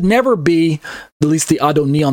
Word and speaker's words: Adoni: It's a title exclusively reserved for God, capital A-D-O-N-A-I. And on the left, you have lowercase A-D-O-N-I, which is pronounced Adon plus E or Adoni,